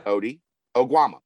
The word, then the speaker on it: Ody Oguama: Oh, it's easy. Odie, like uh, Garfield. – Yeah. Ody Oguama.